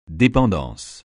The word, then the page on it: dépendance: français